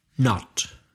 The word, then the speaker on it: knot: Knot. Can you spell knot? – K-N-O-T, which is exactly the same pronunciation as the negative knot, N-O-T.